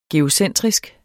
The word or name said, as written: geocentrisk